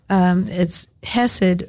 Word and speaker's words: chesed: It's hesed. In English, you would spell it C-H-E-S-E-D. In Hebrew, it's chet, samech, dalet.